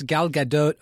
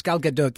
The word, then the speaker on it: Gadot: No, it doesn't rhyme with Bridget Bardot. – Actually, I think it. I'm not. It's Gal Gadot, but it's Gal Gadot. That's how. – Gal Gadot. You pronounce the T. Right. – Yeah. And that's because the name is Israeli, not French.